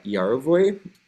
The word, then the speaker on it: Yarovoy: And my name is Mishka Yarovoy, MISHKA underscore YARovoy.